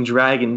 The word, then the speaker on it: Draguex: Your hero is tricked into unleashing a buff bodybuilding dragon named Draguex. It's like a Pokemon. – I think it's Drago. – Drago? Just Drago. The X is silent.